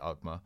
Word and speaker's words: Alkmaar: AZ Alkmaar? How do you pronounce it? – RZ Alkmaar.